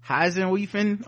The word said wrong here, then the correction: Hefeweizen: or some shit. – Hefeweizen? – There you go. Hefeweizen.